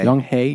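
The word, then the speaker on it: Young-hee: Young Hee. Young Hee. – It's like Hey, but the letters are switched.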